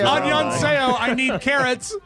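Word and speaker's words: Annyeonghaseyo (안녕하세요): Annyeonghaseyo.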